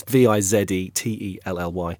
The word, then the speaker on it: Vizetelly: Henri Vizetelly. I hope I've pronounced that right. It's Vizetelly. And he has published several of Zola's books.